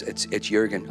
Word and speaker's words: Jürgen: And I said, oh, I said, guys, I said, you probably noticed my name is Jürgen with the J, looks like Jürgen. Yeah, we're figuring out how to pronounce it. I said, yeah, it's Jürgen. Oh, it looks like Jürgen. I know, I was born in Germany.